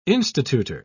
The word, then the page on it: institutor: British English